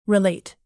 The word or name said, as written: relate